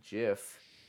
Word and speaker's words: GIF: But graphic G with a hard G. – Yeah. Hard G. – Doesn't make sense that it'd be GIF.